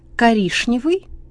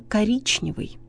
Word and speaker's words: коричневый: Например, «коришневый» – ошибка, правильно «коричневый», «молочный» – ошибка, правильно «молочный», «грешневая каша» – ошибка, правильно, гречневая каша.